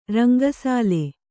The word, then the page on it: ರಂಗಸಾಲೆ: ranga sāle; Kannada